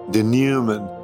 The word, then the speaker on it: denouement: Denouement. It's actually from the French, so we don't pronounce the final T, though you might occasionally hear me say denouement. But we don't pronounce that final T, which is fitting since the Jonah story doesn't merely neglect to cross its final T, but leaves it out altogether.